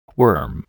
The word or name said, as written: worm